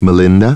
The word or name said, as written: Melinda